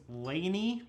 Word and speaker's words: LANY: The pronunciation of their name is Laney, but it's spelled L-A-N-Y, all caps.